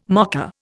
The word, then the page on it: mocker: Indian